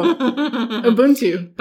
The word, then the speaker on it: Ubuntu: And so Ubuntu.